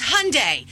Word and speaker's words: Hyundai: And then we found out that Hyundai had a recall. And I said, Mom, it's Hyundai. Hyundai.